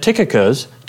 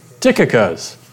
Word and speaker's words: Tychicus: If some of you have been around me, you've probably heard me pronounce that Tychicus. And when I was working with Carl on that, he corrected me. The CH is actually pronounced with a K.